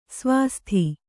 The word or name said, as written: ಸ್ವಾಸ್ತಿ